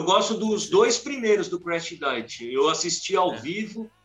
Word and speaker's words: Crash Diet: Me corrijam se eu pronunciar errado o nome da banda, por favor, porque né, meu inglês é horrível mas tem a Crash Crash Diet. – Crash Diet.